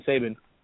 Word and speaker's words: Saban: All right, I'm going to save the other things here, and I'm going to save Saban, and we'll talk about. – Oh, you said it right the first time.